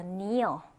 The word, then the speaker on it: kneel: kneel. 자, kneel. 이건 무릎을 꿇다라는 동사가 되죠. 이때도 마찬가지로 K는요, 네, 발음을 하지 않고 그냥 kneel이라고 읽어주시면 되겠습니다.